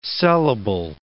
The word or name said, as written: sellable